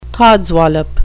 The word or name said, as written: codswallop